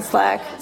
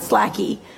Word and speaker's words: Slack: Slack. It was first, kind of, yeah. – The beginning. – Yeah. – Slacking. – We run our whole company on Slack. Slack, not Slacky.